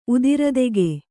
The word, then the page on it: ಉದಿರದೆಗೆ: kannada